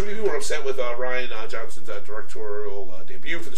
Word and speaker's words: Rian: So many people were mad about Rian's direction with the movie that J.J. – Oh, is that how you say it? – Actually, it is Ryan. Okay. I always call him Rian because I'm fancy.